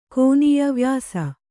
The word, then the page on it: ಕೋನೀಯ ವ್ಯಾಸ: Kannada